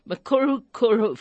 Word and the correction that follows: Morukuru: family de Hoop. doesn't trip off my tongue easily. Morukuru.